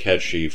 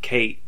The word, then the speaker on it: Cait: Ketch, or as a lot of people pronounced it, Kate, is spelled C-A-I-T.